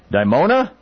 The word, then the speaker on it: Dimona: He also wrote, he wrote a pioneering 1989 book about Israel's nuclear weapons program called Dimona? Is that how it's pronounced? – That is.